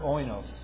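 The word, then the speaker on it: oinos (οἶνος): Now, as we remember, right, that the Greek word here for wine is the word oinos, O-I-N-O-S, oinos.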